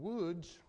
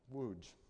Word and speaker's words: Łódź: In Łódź, you might think I'm mispronouncing that city name, Łódź, to the north, it was another massive ghetto that was established in 1940.